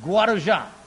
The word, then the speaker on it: Guarujá: Guarujá.